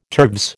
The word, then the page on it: turves: American English